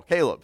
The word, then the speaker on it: Caleb: Caleb. He's a descendant of Cabal, that great man of faith. I said it wrong again, didn't I? Somebody say it for me right. Caleb.